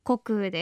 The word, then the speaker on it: 穀雨: ようございます高橋真理恵です 4 月 20 日は二十四節気の穀雨穀物の雨と書いて穀雨